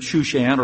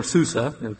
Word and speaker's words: Shushan: best we can tell is that it was probably written, apparently written, by a Jew who lived in Shushan or Susa. Both words or both ways of pronouncing the city are used during the time of Persian rule.